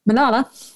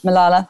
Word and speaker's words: Malala: How do you pronounce that? – Malala. – Malala, thank you.